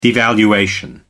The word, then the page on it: devaluation: American English